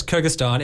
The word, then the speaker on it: Kyrgyzstan: I've got to get this pronunciation right. You lived for 9 years in Kyrgyzstan. – Yes, Kyrgyzstan.